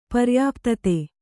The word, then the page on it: ಪರ್ಯಾಪ್ತತೆ: paryāptate